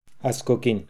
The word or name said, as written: Askokin